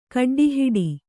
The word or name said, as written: ಕಡ್ಡಿಹಿಡಿ